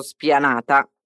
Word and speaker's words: spianata: Come tutte le parole italiane che iniziano con S, seguite da una consonante, facciamo attenzione a non metterci nessuna vocale prima di questa S, ok? Quindi, spianata. Spianata. Il segreto è pronunciare la S muta.